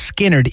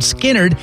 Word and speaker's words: Skynyrd: The gym teacher's name was Leonard Skinnerd, but they changed the way they spelled it, so I'll spell it for you because Skinnerd is your key word that could win you tickets to the concert. It's SKYNYRD.